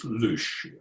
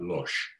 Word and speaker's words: loosh: You know, the, one of the terms for that is loosh.